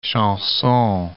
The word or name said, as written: chanson